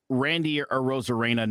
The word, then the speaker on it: Arozarena: And that's this Randy Arozarena nonsense.